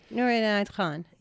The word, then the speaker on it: Nur Inayat Khan: The book that I read about Nur Inayat Khan, I'm going to pronounce it wrong. – That's right. That's right. Nur Inayat Khan.